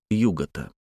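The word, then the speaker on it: Yuggoth: В оригинале Лавкрафт так выстраивает стихотворный размер, что ударение в обоих отрывках падает на первый слог «югота».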